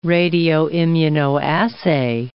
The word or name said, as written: radioimmunoassay